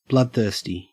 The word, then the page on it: bloodthirsty: Australian English